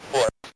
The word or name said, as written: foot